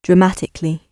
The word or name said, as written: dramatically